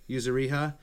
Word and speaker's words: Yuzuriha: Yuzuriha out to join like the other guy Tsukasa.